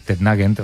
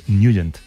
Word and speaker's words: Nugent: Hombre, siempre lo hemos llamado en el barrio Tetnagent o Tetnagent. – Claro, yo era de Tetnagent. – Pero la pronunciación correcta es Nugent.